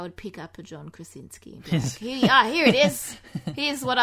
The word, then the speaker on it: Krasinski: John Krasinski. – Krasinski.